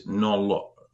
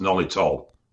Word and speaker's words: Nolotil: And second one is Nolotil, often pronounced Nolitol. – That's what I've always said, Nolitol. – I've always said Nolotil as well, but it's actually spelled Nolotil.